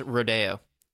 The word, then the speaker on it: rodeo: This was literally my first rodeo. – It's pronounced rodeo. It's your first rodeo.